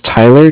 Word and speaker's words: Tyler: My name is Tyler, T-Y-L-E-R. – Uh-huh. – And I'm calling with Cardiff. It's spelled C-A-R-D-I-F-F, as in Frank.